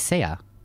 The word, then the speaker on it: Sia: Yeah, it is Sia.